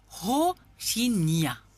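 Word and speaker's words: Rocinha: Alors, le Ro, il faut bien prononcer parce que le R en portugais, ça sonne comme un H. Alors, c'est plus Rohingya, si on prononce bien.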